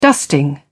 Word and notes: dusting: British English

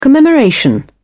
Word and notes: commemoration: British English

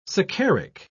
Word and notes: saccharic: British English

